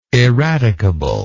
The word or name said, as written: eradicably